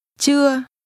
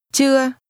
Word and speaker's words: chưa: Chưa. Chưa. Chưa. – This is pronounced with a mid-level tone. Say again, not yet. – Chưa.